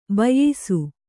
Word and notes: ಬೇಯಿಸು: bēyisu

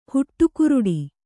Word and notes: ಹುಟ್ಟುಗುರುಡಿ: huṭṭuguruḍi

